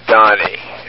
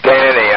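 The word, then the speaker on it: Dhani: And I think it's pronounced Donnie Harrison. – What'd you say about Harrison? – I don't think it's Danny. I think it's Donnie. – I didn't quite. It's pronounced differently. It just looks like Danny. – Well, I think it's D-H-A-N-I.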